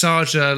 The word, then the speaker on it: Czaja: so I had to be careful because it's Czaja like massager, right? – Rhymes with massage. Yes, that's how I introduce it. Like massage rhymes with Raja.